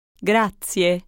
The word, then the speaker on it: grazie: I hope you heard all the sounds in grazie, especially the last vowel.